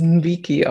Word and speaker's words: Nweke: Ich hoffe, ich spreche das richtig aus. Das ist ein nigerianischer Name, der sich Nweke schreibt. Und meinen Recherchen nach spricht man es Nwiki aus.